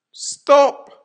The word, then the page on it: stop!: American English